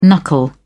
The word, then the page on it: knuckle: British English